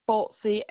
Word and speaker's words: Hulsey: Hulsey, H-U-L-S-E-Y.